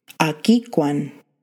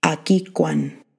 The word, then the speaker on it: agikwan: So this is how you say girl in Blackfoot, agikwan, agikwan, girl. Akikwan.